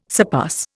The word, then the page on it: surpass: Indian